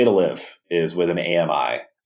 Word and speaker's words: AMI: Anyone who's heard my whole shtick on AMI has three syllables knows exactly what I'm talking about.